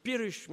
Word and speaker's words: Piaras: Alors un autre Irlandais avec un nom gaélique, donc Pirish, mais ça s'écrit Piaras McHenry,